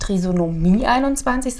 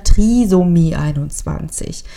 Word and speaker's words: Trisomie 21: Mir fällt jetzt hier schon auf, dass ich das Wort immer falsch ausspreche. Das heißt nicht Trisomie 21, sondern Trisomie 21.